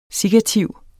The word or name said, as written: sikkativ